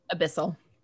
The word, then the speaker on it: Abyssal: How do you say it? Celestial Elvish, and abyss. Say it again. – Abyssal. – Abysmal. – Abyssal. Abyssal. Thank you.